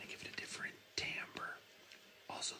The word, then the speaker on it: timbre: spelled T-I-M-B-R-E. Looks like timbre, pronounced timbre.